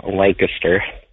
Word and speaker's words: Lancaster: And I know you Californians say it Lancaster, but it's actually pronounced Lancaster.